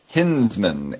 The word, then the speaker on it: Hinzman: I think it's pronounced Hinsman. H-I-N-Z-M-A-N.